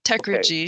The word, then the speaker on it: Techrigy: Well, I'm going with the soft G, as in technology. So Techrigy.